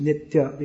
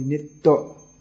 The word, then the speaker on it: nitya: There's another when it comes after, just like nitya in Sanskrit, nitya becomes in Bengali nitto, the ya becomes almost silent.